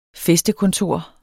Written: fæstekontor

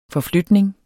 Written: forflytning

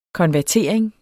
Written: konvertering

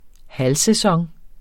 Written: halvsæson

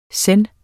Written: zen

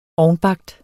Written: ovnbagt

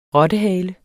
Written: rottehale

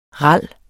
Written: RALS

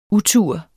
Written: utur

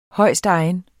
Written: højstegen

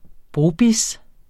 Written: brobizz

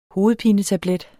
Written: hovedpinetablet

